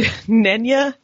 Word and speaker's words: Nenya: If you were offered Nenya, Narya, or Vilya, and I don't know if I'm pronouncing it.